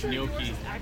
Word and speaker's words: gnocchi: G-N-O-C-C-H-I-M-E. – And you pronounced it? – Gnocchi.